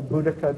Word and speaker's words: Boudica: Another set of characters that I like to think about are, this is Augustus and Queen Boadicea, or Boudica, depending on how you pronounce it.